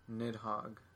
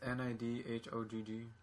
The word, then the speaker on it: Nidhogg: How you say its name? – Nidhogg. – Because it's spelled like all crazy. – It's just Nidhogg. – Okay, that's not crazy.